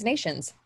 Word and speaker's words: Nations: So in September, a man named Daniel Nathans was arrested in Colorado. – I think it's Nations. – Yeah. – Spelled like Nations.